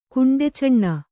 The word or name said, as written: ಕುಂಡೆಚಣ್ಣ